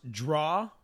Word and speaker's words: drow: No, a drow, D-R-O-W... – Sometimes pronounced draw.